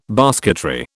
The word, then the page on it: basketry: British English